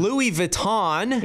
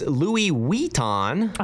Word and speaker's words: Louis Vuitton: Louis Vuitton, as we call it. That's not how it's pronounced. It's Louis Vuitton. Louis Vuitton.